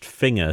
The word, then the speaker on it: Finger: I found out recently it's pronounced Finger to rhyme with Zinger.